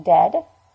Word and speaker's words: dead: And dead? It's said like bed, not bead. For goodness sake, don't call it deed.